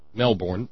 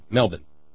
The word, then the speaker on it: Melbourne: It's spelt Melbourne, the proper pronunciation being Melbourne, but most Australians just say Melbourne.